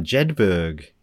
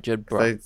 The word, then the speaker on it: Jedburgh: This next one's from Billy in Jedburg in Scotland. – Huh. – Or Jedburgh, if it's like Edinburgh. – Oh, right. Jedburgh. Jedburgh. – It looks Berg, but I think they maybe pronounce it Burgh.